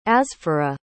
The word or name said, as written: asfoureh